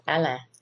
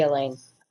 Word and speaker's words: Elaine: Or if you're a coon ass, it would be called Alain. But yeah, to Americans, it would be Elaine.